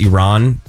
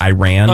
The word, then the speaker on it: Iran: One that really bothers me, and I'm not trying to get political, but it's Iran, not Iran.